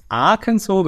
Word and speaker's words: Arkansas: Ich habe es auch gerade mal in einer TV-Sendung wieder falsch gesehen, weil es gibt ja einen anderen Bundesstaat Kansas, aber Arkansas wird tatsächlich französisch ausgesprochen. Das ist ein altes Wort der Quapaw-Indianer.